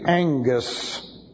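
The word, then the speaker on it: engus (ἐγγύς): And then we have again the ellipsis, which requires the verb to be, and then the adverb of time, engus, which looks like this. That's E-G-G-U-S, and it's pronounced E-N-G, engus.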